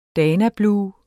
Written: danablu

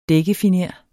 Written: dækkefiner

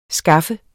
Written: skaffe

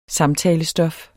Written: samtalestof

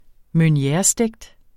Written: meunierestegt

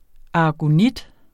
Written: aragonit

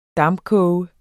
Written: dampkoge

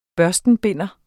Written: børstenbinder

